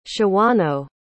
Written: shawano

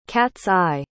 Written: cat's-eye

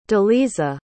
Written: deliza

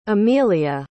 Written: emelia

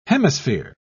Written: hemisphere

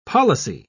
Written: policy

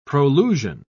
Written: prolusion

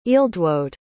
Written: ealdwode

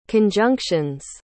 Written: conjunctions

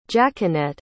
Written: jaquenette

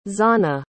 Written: Zana

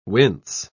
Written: wince

